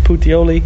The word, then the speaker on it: Puteoli: Puteoli.